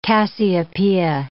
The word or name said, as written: Cassiopeia